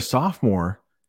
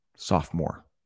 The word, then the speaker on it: sophomore: Sophomore. I know there's an O in the middle, but we don't pronounce it. Sophomore.